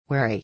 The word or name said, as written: wary